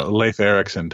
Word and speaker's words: Leif Erikson: Leif Erikson type thing.